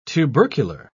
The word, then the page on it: tubercular: British English